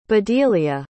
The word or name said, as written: bedelia